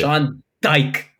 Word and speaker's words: Dyche: How you say Sean Dyche.